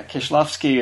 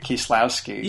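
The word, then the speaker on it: Kieślowski: And I think, um, yeah, for the for the for the most part, W's are pronounced as a you know hard V, so it's Kishlowski. – Yeah, Kishlovsky is, I think, how you say it.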